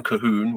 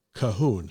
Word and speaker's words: Colquhoun: C-O-L-Q-U-H-O-U-N. Cahoon. – Yes. Yes. If you know anything about how Scots write their names and stuff, names of places, names of things, they're not always the most intuitive.